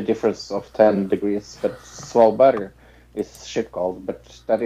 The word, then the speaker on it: Svalbard: Svalbard, yeah.